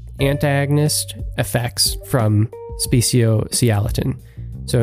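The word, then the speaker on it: speciociliatine: I think I called it speciosiliatine. It's speciosialatin.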